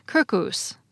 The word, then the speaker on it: circus: In Latin, it shows up as circus, or as it would have been pronounced in classical Latin, circus.